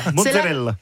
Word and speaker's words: mozzarella: On parle de mozzarella aujourd'hui. Absolument. Déjà, est-ce qu'on prononce bien ? C'est mozzarella ? – Mozzarella. – Mozzarella. Mozzarella. – Vous le dites très très bien.